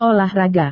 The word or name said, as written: olahraga